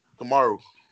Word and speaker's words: Kamaru: I mean, well, Karamu got the belt. I mean, I was pronounced this man's name wrong. – Who, Usman? – Yeah, I forget to pronounce his first name wrong. – Kamaru. – Yeah, Kamaru.